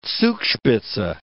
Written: Zugspitze

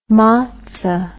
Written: motza

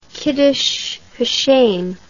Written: kiddush hashem

